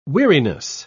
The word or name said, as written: weariness